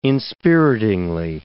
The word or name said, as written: inspiritingly